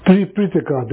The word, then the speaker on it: Tripitaka: Denism, Tripitaka, that's how to say it in Sanskrit, Tripitaka.